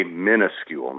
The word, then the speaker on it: minuscule: And the word is properly spelled M-I-N-U-S-C-U-L-E because it comes from the Latin minus, minus in English, which means less. So the traditional pronunciation, you're probably going, your jaw's going to drop, is minuscule. And that's the way I say it, just to make a point.